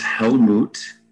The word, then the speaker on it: Helmut: Yes, it's Helmut. And then technically my last name's Domagalski, but hell like damnation, and then moot like a cow goes moo with a T at the end.